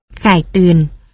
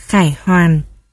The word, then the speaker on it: Khải Tuyền: Khải Tuyền còn đọc là Khải Hoàn. Khải Tuyền là chính âm, có nghĩa là thắng trận trở về.